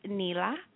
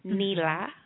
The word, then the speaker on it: nila: And that is spelled N as in Nathan, A-R-A-N-G-I, Narangi. And the last color is going to be blue, of course. And it is pronounced Nila. Nila. And it is spelled N-I-L-A. That's Nila.